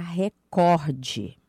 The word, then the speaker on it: recorde: Então, nós devemos pronunciar recorde.